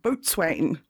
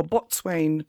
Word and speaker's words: Boatswain: So the dog that was refused residence at Trinity College was called Boatswain, or Botswain, depending on your pronunciation.